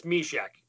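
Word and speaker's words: Myšák: I guess we should probably make sure we pronounce his name correctly. It is. – Well, that's okay. – It is Jan. Go ahead. It's Mysek. – Mysek. All right.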